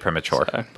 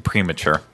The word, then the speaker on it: premature: A little bit premature. Premature. Not premature. That's it. You're putting the emphasis on the wrong syllable.